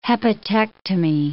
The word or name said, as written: hepatectomy